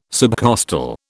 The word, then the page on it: subcostal: British English